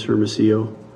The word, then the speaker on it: Hermosillo: Ray Dennis Hermosillo.